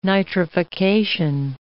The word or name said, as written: nitrification